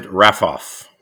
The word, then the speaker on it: Raffauf: It's pronounced Americanized Raffauf. – Raffauf. – R-A-F-F-O-F-F, even though it's A-U-F at the end.